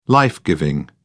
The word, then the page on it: life giving: British English